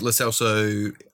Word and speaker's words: Lo Celso: The other thing is Lo Celso is how you say it.